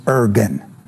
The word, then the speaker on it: Irgun: Anyway, Rick Wilde said Ergen. – Ergen. So Ergen's that was that one. – Yeah, I-R-G-U-N.